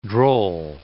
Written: droll

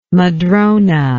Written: madrone

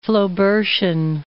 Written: Flaubertian

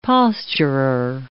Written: posturer

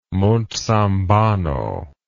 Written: monzambano

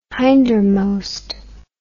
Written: hindermost

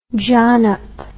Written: jonnop

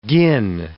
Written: gin

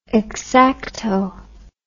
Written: xacto

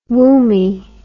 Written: womby

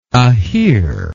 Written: Ahir

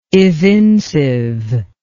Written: evincive

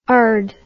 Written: ard

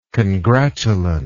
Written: congratulant

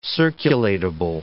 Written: circulatable

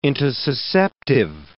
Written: intussusceptive

